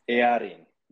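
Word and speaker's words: Eärien: And did you, so forgive me if I'm pronouncing her character name wrong, Isildur and Arian? – Arian, yes.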